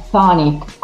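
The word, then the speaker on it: chthonic: in questa nota lei spiega la pronuncia del termine e dice la seguente cosa, nota che probabilmente tra l'altro spungeremo perché la pronuncia di Ctulucene e eh, anche di Ctonic in inglese dovrebbe essere con una T dolce e una K muta. Ctulucen, Tonic, quindi eliminare la C fondamentalmente dalla pronuncia.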